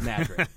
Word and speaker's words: Madrid: It's spelled like Madrid. It's called Madrid.